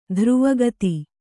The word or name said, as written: ಧ್ರುವಗತಿ